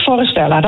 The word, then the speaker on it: Forestella: Spreek je het zo goed uit eigenlijk? Of, Ja, helemaal goed. Forestella, dat klopt.